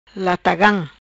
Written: Latagam

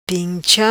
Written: Pincha

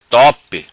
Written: Tope

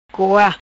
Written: Coa

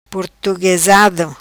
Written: Portuguesado

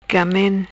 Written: Gamen

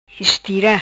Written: Estira

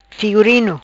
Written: Figurino

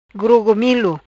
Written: Grogomilo

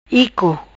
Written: Ico

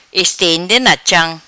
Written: Estende na cham

